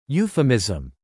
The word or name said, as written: euphemism